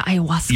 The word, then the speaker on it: ayahuasca: Ayahuasca? – Yeah, ayahuasca.